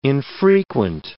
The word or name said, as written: infrequent